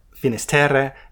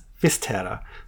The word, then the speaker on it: Fisterra: to the village known in Spanish as Finisterre and in Gallego as Fisterra. So I think you're going to go with the Gallego pronunciation? – Yeah, I'm in full-on Gallego mode right now.